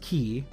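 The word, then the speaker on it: key (χ): So Kingdom Hearts X, which is pronounced key, as in the, I believe, the Greek numeral? But it looks like an X.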